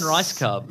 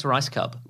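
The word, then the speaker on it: Rajskub: We got Mary Lynn Rice Cub. – Yep. – I wonder if they weren't sure whether is that how you say her name? – Right, I think it's Rice Cub.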